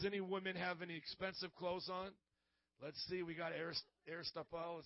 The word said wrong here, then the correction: Aeropostale: is that how you pronounce it? Aeropostale, then we got some expensive clothes.